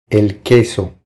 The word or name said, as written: El queso